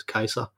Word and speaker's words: Caesar: Jamen Romeriet øh, efter Augustus, som bliver enehersker, øh, kommer så, får så en række, lang række eneherskere, dem kalder vi kejser efter Julius Caesar, som faktisk udtales kejser på øh, latin.